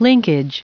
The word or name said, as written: linkage